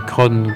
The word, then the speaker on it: Craon: c'est la chanson de Craon. Il faudrait prononcer Cron comme les gens du coin.